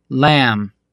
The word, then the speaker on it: lamb: Буква «Б», стоящая после буквы «М» в конце слова, не произносится. Послушайте примеры. – Jam. I am. Lamb.